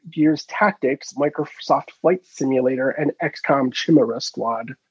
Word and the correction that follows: Chimera: I fucking knew I was gonna say it like that. – Chimera. – XCOM Chimera Squad. – Chimera.